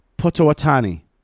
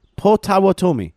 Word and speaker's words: Potawatomi: And he continues, because I don't know how you knew that, but I'm going to butcher this, but I am Potawatani. – Oh, wow. – Potawatomi.